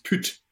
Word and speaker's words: pyt: But it's spelled P-Y-T, and it's pronounced put.